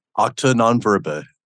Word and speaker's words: Acta non verba: Acta non verba? – Acta non verba.